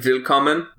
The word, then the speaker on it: Willkommen: W would pronounce a V. – Willkommen? – Yeah, like Willkommen.